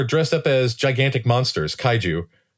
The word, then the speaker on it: kaiju: Anyway, many, many occasions where she has she wants to create costumes for the kids for various plays, a kaiju wrestling event, all sorts of things.